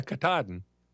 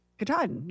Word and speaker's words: Katahdin: Yeah, Katahdin. – Katahdin, yeah.